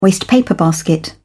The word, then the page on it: wastepaper basket: British English